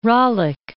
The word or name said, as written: Ralik